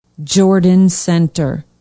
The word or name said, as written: jordan center